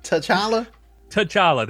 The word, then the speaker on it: T'Challa: T'Challa.